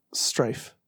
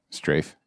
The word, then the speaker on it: strafe: I thought it was Strife. Now you're fucking with my chi. I gotta find out now. – Strafe. – Strife. Well, wouldn't Strife be with an I? – Pronounce. – Strafe. American Strafe.